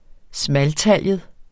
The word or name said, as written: smaltaljet